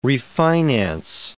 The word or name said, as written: refinance